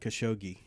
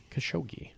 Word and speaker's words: Khashoggi: The character played by Richard Libertini was actually based on a real-life Saudi business and arms dealer named Adnan Ashkau. How do you say that name? The third one down, Ashkadi? – Adnan Khashoggi. – Khashoggi.